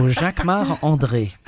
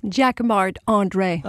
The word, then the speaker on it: Jacquemart-André: So Jacquemart-André. – And say it so we can understand it. – Jacquemart-André.